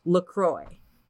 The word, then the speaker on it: LaCroix: I read an article and it's pronounced LaCroix. Rhymes with Roy.